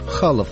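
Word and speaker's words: Chaloff: Конечно, его фамилия пишется через два «Ф», а русское произношение на российских сайтах «Серж Халов». Его дед эмигрировал из России, и именно он носил фамилию Халов.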